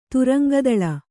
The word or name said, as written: ತುರಂಗದಳ